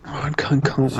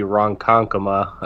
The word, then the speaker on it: Ronkonkoma: Did I say it right? Ron Con. Coma? – Ronkonkoma. I don't. – I, I have not. Crazy stuff. I'm gonna. I'm gonna chime in. I'm gonna chime in because that sounds like it's from Long Island, and it's gonna be Ronkonkoma.